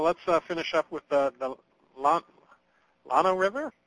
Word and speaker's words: Llano: Yeah, it's the Llano if you're going to do it correct. If it's out of state, it's the Llano.